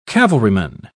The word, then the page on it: cavalryman: British English